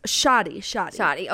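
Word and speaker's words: Shadi: Sadi? – Okay. – Maybe, gosh, I forget. How do you, Shoddy. – Okay. That's how I pronounce it. – Shoddy's like a melody.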